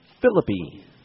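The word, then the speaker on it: Phillippe: Maybe he's like Ryan Philippi now. How do you say they pronounced it? – Philippi.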